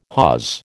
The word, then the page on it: hawse: American English